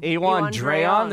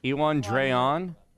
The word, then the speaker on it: Iwan Rheon: Iwan Rheon. There's no D. – Iwan Rheon.